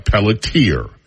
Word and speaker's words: Pelletier: It looks like Pelletier, and that is the way that many people would pronounce it. However, I happen to know that there's a French pronunciation that is also Pelletier. P-E-L-L-E-T-I-E-R, Pelletier. Jackson, 58, and Lena Pelletier, or Pelletier, share two adult sons and the same great-grandparents.